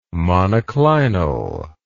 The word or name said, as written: monoclinal